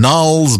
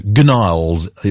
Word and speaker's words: Gnarls: Gnarls Barclay. Silent G, you know. Gnarls, it would otherwise be pronounced.